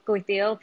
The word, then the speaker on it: Kuy Teav: So the noodle soup is called Kuay Teow Phnom Penh. It's pronounced, it's spelled K-U-Y. T-E-A-V, and then it's named after the capital, Phnom Penh, in Cambodia.